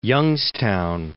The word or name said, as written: Youngstown